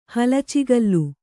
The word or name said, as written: ಹಲಚಿಗಲ್ಲು